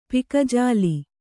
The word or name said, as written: ಪಕ್ಕಜಲ್ಲಿ